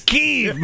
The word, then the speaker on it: Kyiv: It's one syllable, man. I stumbled on three. You're stumbling on one. – Kyiv. – It's Kyiv, man.